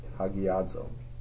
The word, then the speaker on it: Hagiazo: Hagiazo, and then right after that is the English, an English transliteration, Hagiazo. That's how you'd say it. It even helps you how to pronounce it, Hagiazo.